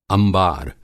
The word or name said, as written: अंबार